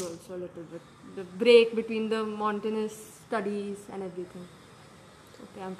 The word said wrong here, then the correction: monotonous: No, you just mispronounced monotonous. – Monotonous, yeah.